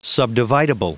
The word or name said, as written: subdividable